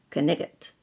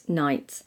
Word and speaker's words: knight: Back in the day, in the Middle Ages, all the letters in night, K-N-I-G-H-T, were pronounced, knigget. But over the years, we've simplified pronunciation, so we pronounce it night, but retain the old spelling so we don't confuse it with the dark bit that follows day.